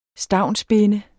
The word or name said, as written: stavnsbinde